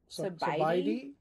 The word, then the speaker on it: SaBaiDee: All right, so the Thai food we got is in Clarksburg, and it is called SaBaiDee. – SaBaiDee. – It's SaBaiDee.